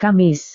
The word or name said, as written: kamis